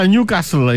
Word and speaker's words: Newcastle: Μανιάνα, το επαναλαμβάνω, παιδιά, Newcastle είναι. Δεν προφέρεται το ΤΑΦ.